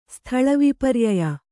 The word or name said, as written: ಸ್ಥಳವಿಪರ್ಯಯ